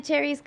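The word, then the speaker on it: Chairez: You guys can find me at Lana Cherry's Comedy.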